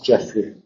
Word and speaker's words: Geoffrey: También dentro del cuento del abogado de Geoffrey Chaucer, Geoffrey, pronunciamos Geoffrey desde ahora en adelante, eso tiene una versión de la historia de Crescencia, que es una antigua historia de esta época.